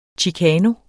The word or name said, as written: chicano